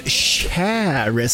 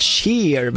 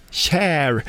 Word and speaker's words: Cher: The Shoop Shoop Song med Share, som ska uttalas. Jag har alltid sagt Share, men det är fel. Share. Jag får lita på våra musikläggare.